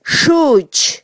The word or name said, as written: xuch